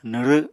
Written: nwrw7

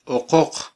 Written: oqoq

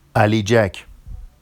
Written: aliʤac

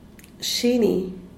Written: shini

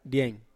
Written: diain

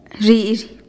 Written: zhi'izhih

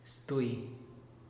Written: stuy

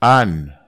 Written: an

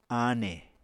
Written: ane

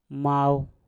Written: mau

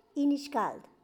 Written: Quialana

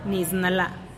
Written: nis lla

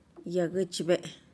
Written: yaguchbe'eh

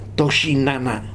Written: tojinana

